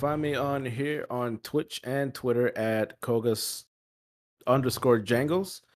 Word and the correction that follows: Koga_Jangles: Did I say that right? I feel like I made a mistake. – You did say Kogas instead of Koga. – Find me here at Koga_Jangles.